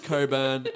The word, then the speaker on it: Cockburn: Craig Cockburn, but his name, it's pronounced Coburn, everybody. It's spelt Cockburn, but it's pronounced Coburn. – Oh, like C-O-Q-U-E? – It is spelled C-O-C-K.